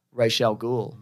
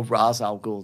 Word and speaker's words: Ra's al Ghul: Ra's al Ghul. Or Ra's al Ghul, depending how you say it.